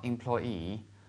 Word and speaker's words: employee: 大家看一下这句话应该怎么说？I'm a federal employee. I work for the State Department. OK, I'm a federal employee. 首先有个单词是发音比较在英语里面可以说发音比较变态一点的哈，就是它这么长一个单词的话，它居然在最后两个字母它才那个。啊，把重音读出来，就是雇员这个词。